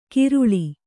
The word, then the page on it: ಕಿರುಳಿ: kiruḷi